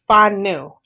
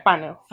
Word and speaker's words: Fanou: But it's pronounced Fanu. – Oh, Fanu? – Yeah, Fanu. Fanu. Exactly. – F-A-H-N-U. Fanu. – More like W? – F-A-N-O-U.